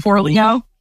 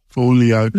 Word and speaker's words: Forleo: Forleo. – Forleo.